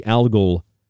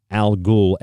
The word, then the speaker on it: Algol: The movie Al Ghul, Al Ghul, A-L-G-O-L, the pronunciation comes up in Batman as Ra's al Ghul, the leader of the Brotherhood of the Demon.